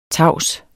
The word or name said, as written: tavs